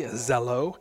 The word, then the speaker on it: Zello: It's pronounced hello with a Z, Zello.